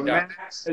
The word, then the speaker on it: Max: So Max is just M-A-X, and then Winfrey is spelled just like Oprah's last name, W-I-N-F-R-E-Y.